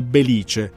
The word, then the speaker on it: Belice: Belice. Si pronuncia proprio con l'accento sulla I.